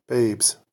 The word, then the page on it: babes: American English